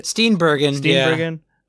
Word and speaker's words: Steenburgen: how do you say her last name? Mary Steenburgen? Is that right? – Steenburgen.